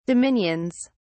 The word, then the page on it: dominions: British English